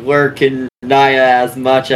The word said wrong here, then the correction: Naya: That's because you're pronouncing Naya wrong. It's Nya.